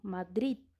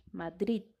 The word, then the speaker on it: Madrid: Por exemplo, Madrid, em espanhol, a capital da Espanha se escreve com D final, Madrid e a gente pronuncia unicamente até o D, não adiciona um vogal mudo, uma letra muda, enfim, como existe isso em português. A gente unicamente pronuncia até a consoante, Madrid.